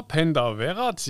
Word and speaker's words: Pentaverat: Fangen wir Formular Pentaverat, ja, ist eigentlich. Ähm, Hast du richtig ausgesprochen? – Pentaverat ins äh, im Deutschen aussprechen, ja. – Das ist schon richtig.